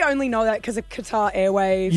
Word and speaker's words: Qatar: Is it guitar? Like guitar. A guitar? – Qatar. – No. – Qatar, isn't it? – Correct. – Oh, yeah. Qatar.